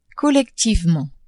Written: collectivement

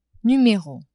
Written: numero

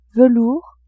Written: velours

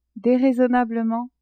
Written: deraisonnablement

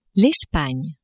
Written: Espagne

